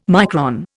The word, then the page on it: micron: Indian